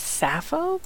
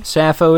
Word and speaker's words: Sappho: We are here with Sappho, or is it Sappho? Sa or Sa? – I, I pronounce it as Sappho, but let me. – Sappho it is. That makes sense. S A P P H O.